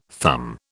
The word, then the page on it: thumb: British English